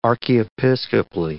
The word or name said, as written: archiepiscopally